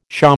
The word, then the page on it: pshaw: American English